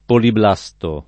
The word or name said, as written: poliblasto